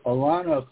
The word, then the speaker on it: Alano: So Alano clubs is how we pronounce it here.